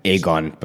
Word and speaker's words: Egon: So I reached out to you because I had read an interview that you and a couple other journalists had conducted with Egon Krenz. Am I saying that correctly? – Yeah, Egon, but...